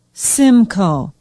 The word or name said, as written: symco